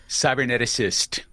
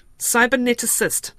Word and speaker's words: cyberneticist: Mark Pesce, technology correspondent and futurist and cyberneticist, I'm reading here. Have I pronounced that correctly? – Cyberneticist. – Cyberneticist. There's another syllable.